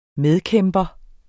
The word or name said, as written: medkæmper